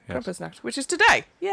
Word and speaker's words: Krampusnacht: Well, it's happy Krampusnacht.